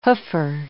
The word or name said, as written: hoofer